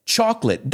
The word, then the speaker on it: chocolate: Chocolate, dos sílabas, just like family.